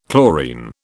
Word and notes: chlorine: British English

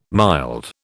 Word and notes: mild: British English

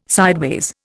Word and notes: sideways: Indian

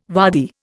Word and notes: waddy: Indian

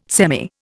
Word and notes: semi: Indian

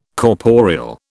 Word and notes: corporeal: British English

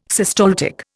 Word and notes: systaltic: Indian

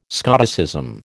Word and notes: Scoticism: American English

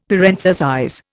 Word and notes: parenthesize: Indian

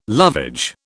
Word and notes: lovage: British English

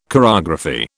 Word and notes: chorography: British English

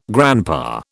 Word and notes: grandpa: British English

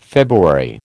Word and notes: February: American English

